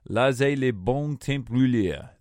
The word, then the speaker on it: Laissez les bons temps rouler: Laissez les bons temps rouler.